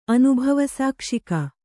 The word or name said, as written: ಅನುಭವಸಾಕ್ಷಿಕ